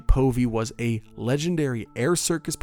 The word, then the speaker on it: Povey: Povey might be pronounced Povey, P-O-V-E-Y. I might stick with Povey. That sounds a little better.